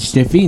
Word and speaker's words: Jif: Jiffy, now it's Jiff. – I know, it's spelled Jiff. It's never been Jiffy. – It's never been Jiffy, it's just J-I-F.